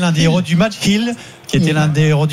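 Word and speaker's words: Gil: C'est ce gardien Orlando Hill, je l'ai bien prononcé, on dit Hill ou pas? – Si, Orlando Hill. Oui.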